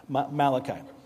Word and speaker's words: Malachi: Last week I said Malachi. That's not how you say it. Okay, he's not Italian. Malachi.